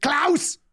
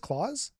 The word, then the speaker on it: Klaus: I was about to ask you, when I see K-L, I automatically pronounce it Klaus. – Okay. – Do you think it's actually just Klaus? – No. – It's Klaus! – No. I mean, yes, that is not an incorrect pronunciation. – Uh-huh. – It's not enforced either.